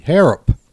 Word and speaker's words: Harrop: Harrop. – H-O-R-R. – H-A-R-R-O-P. – Right. – Harrop. – Okay, here it is. It's in blue. H-A-R-R-O. Alan Harrop. – P. – O-P. O-P. Harrop.